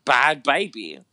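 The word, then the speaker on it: Bhabie: Um, well, no, it's, it's pronounced B A H B Y, right? – Yeah, bad baby.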